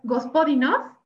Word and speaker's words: Gospodinov: Y les platico, Física de la Tristeza es una novela en la que eh, vemos a este escritor Gospodinov, que lo pronunciaste bien según yo,